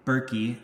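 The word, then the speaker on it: Bereki: Sure, it's Adam, A-D-A-M, mm-hmm. – Last name Berkey, it's spelled B like boy, E-R-E-K-I.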